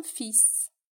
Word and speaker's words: fils: Agora eu vou te dar uma pequena listinha de palavras, as mais comuns, em que você deve pronunciar o S final. Vamos lá? Un bus, um ônibus. Un sens, um sentido. Un fils, um filho. Mars, março. Un ours, um urso.